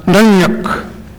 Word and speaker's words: nunnuq: My license plate is N-U-N-N-U-Q, which is a Nupiaq word, correctly pronounced Nunyuk.